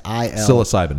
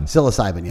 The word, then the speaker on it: psilocybin: Psilocybin. – Psilocybin, yeah.